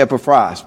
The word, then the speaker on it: Epaphras: And so, well, you know, probably in Greek, if we asked our Greek brethren, how do you pronounce his name, they would probably say Epaphras, because in the Greek Bible, the accent's on the last syllable.